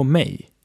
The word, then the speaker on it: mig: Mig is written M-I-G, but is pronounced like M-E-J.